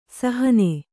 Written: ಸಾಹಣ

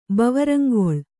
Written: ಬವರಂಗೊಳ್

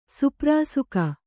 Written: ಸುಪ್ರಾಸುಕ

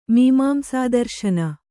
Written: ಮೀಮಾಂಸಾದರ್ಶನ

